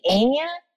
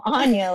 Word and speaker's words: Anya: They're like, Anya? I'm like, no, Anya. Like, you got something on ya.